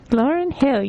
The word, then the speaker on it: Lauryn Hill: I don't know if I'm pronouncing that right, but... – Lauryn Hill, you got it.